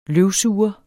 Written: løvsuger

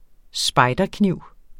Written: spejderkniv

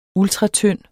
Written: ultratynd

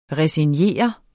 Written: resignere

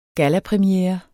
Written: GALLAPREMIERE

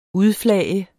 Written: udflage

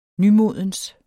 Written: nymodens

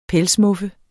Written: pelsmuffe

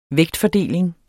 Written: vægtfordeling

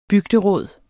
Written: bygderåd

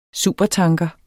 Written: supertanker

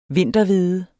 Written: vinterhvede

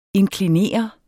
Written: inklinere

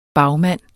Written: bagmand

